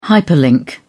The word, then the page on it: hyperlink: British English